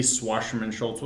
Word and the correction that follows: Wasserman Schultz: Yeah, let's go with Wasserman Schultz.